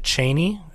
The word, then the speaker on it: Chani: Paul and Chani, who it's Frank Herbert pronounced it Chaney, is you know, he wrote the book. That's how he said it.